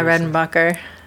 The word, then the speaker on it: Redenbacher: Redenbacher? – Yeah, Redenbacher. – Like, what is that? I mean, R-E-D, it's a last name. R-E-D-E-N-B-A-C-H-E-R.